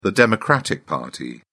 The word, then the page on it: democratic party: British English